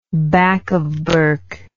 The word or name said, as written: back of Bourke